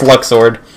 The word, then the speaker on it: Luxord: Yeah, screw you, it's Luxord.